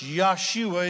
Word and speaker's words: Yeshua: Parenthetically, Joshua is pronounced Yeshua in Hebrew.